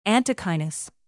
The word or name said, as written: antechinus